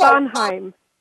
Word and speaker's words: Bonheim: Now, did I say your last name correctly? Bonheim? – Yeah, it's Bonheim.